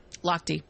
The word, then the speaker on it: Lochte: Here's at Ryan Loesch, who is the swimmer. – Lochte. – Close enough. Is that how you pronounce it? Lochte? – I think so.